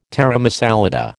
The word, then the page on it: taramasalata: American English